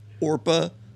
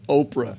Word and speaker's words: Orpah: Yeah, she got so upset that we kept calling Orpah Oprah.